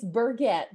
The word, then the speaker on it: Burget: It's Burget. That's okay.